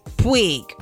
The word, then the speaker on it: Puig: Puig.